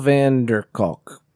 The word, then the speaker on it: Vanderkolk: Vanderskoot is actually pronounced... – Jones. – Vanderkolk? – Oh, that's not bad. Vanderkolk. What is it? – Right here. – I can't see it. – V-A-N-D-E-R-K-O-L-K? – Oh, Vanderkolk. Kolk.